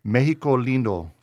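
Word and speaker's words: México Lindo: He is the owner of Mexico, or you say Mexico Lindo.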